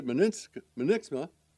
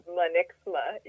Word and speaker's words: Munniksma: Hey, our guest today is Lisa Munniksma.